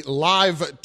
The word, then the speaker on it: LIV: And he will get that from the Live Tour. It's spelled L-I-V, but apparently it's pronounced live.